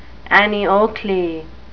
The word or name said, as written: annie oakley